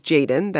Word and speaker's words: Jxdn: He makes music as Jaden, that's spelled J-X-D-N, and his songs have been streamed more than 700 million times.